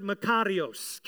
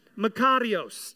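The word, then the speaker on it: Makarios (μακάριος): Well, in its origin, it is the word makarios. Can you all say that word? Makarios. Just roll your R a little bit. Makarios.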